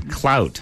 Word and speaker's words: Clout: And before that, Clute from 1977. – Clute. – Since You've Been Gone. – Come on. Clout. – Clout. Is that how you say it? – C-L-O-U-T. – Clute? – I say clout.